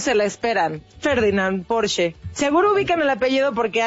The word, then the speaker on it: Porsche: Y solo como un dato cultural, déjenme les cuento que es alemana y su correcta pronunciación es, escuchen, Porsche.